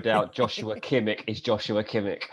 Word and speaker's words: Joshua Kimmich: And again, his first name is a slightly unusual pronunciation. Even to Germans, they don't necessarily expect it would be pronounced this way. But it is Joshua Kimmich. Joshua Kimmich.